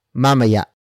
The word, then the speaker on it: mamaya: And finally, we have mamaya. Mamaya. Mamaya. Mamaya is spelled as M, A, M, A, Y, A. Note the glottal stop at the end.